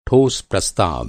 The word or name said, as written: ठोस